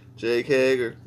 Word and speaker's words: Jake Hager: Jake Hager. – Yeah, buddy. – Is that right? – Yep. – How do I even know what someone say? – Jake Hager.